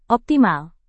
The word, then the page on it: optimal: Indonesian